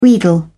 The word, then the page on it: wheedle: British English